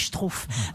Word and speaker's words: Struff: Je dis Struff parce qu'il y a un auditeur qui m'a envoyé un mail pour me dire que je prononçais mal. Ça s'écrit Struff. – Vous aviez dit quoi ? – Bah, ça s'écrit Struff, en fait, si je veux le prononcer à la française, mais on dit Struff.